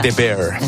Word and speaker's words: The Bear: ¿Pero cómo se pronuncia? – The Bear. – Me lo he dicho bien antes, Bear. The Bear.